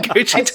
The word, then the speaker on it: Cu Chi: Gucci Tunnels? – yes. But it's spelled C U space C H I.